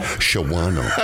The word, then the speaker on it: Shawano: Shawano.